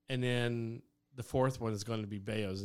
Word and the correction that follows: Bezos: Bezos. – Hell, I'm like, the man has $86 billion. You can get his name right.